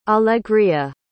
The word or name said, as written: alegria